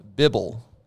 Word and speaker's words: bibble: Word number one, bibble. – Bibble. – It's spelt like Bible, but with two B's. – Bibble. – In the middle. B-I-B-B-L-E. Bibble.